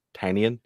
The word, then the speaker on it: Tynion: And first thing, I the note that I think everyone says Tinian wrong, or Tinian. It actually is pronounced Tinian. He has a newsletter called Tiny Onion. I think that's how you're meant to say his name.